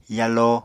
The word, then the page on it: yalo: Apatani